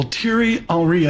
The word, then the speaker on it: Thierry Henry: Thierry Henry, I hope I'm pronouncing you did.